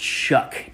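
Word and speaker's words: Shough: But one quarterback that I would play in a one-off is going to be Tyler, I'm told it's pronounced Shuck, even though I don't know how you get Shuck out of how that's spelled.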